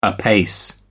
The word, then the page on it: apace: American English